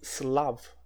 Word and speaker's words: Slav: Eslavo em inglês se pronuncia Slav, a escrever na lousa, Slav.